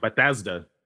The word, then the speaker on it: Bethesda: Bethesda. – Bethesda.